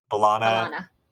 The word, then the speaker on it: B'Elanna: B'Elanna.